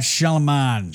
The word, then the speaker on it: Shyamalan: Night Shyamalan.